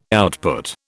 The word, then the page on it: output: British English